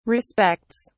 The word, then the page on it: respects: British English